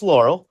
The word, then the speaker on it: Laurel: Is Lauren Hell a person? What is it? – Well, it's pronounced Laurel. – Oh, that's so. Right. It's Laurel, not Lauren.